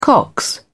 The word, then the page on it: cox: British English